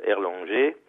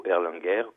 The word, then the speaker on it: Erlanger: comme Jules Erlanger, ou Erlanger, comme on veut le prononcer.